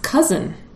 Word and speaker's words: cozen: So C-O-Z-E-N, pronounced cousin. – Yeah, I've seen that word. Never heard it pronounced. But. – So I would have said cozen.